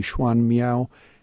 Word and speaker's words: Xuan Miao: It's it's written with Chinese characters, and Genmyo is the Japanese way of pronouncing it. The Chinese way of pronouncing it would be Xuan Miao, excuse my poor Chinese.